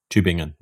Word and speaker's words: Tübingen: Tübingen, yes.